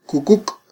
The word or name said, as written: kukuk